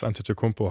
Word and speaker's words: Antetokounmpo: Janis Antetokounmpo heißt er ausgesprochen.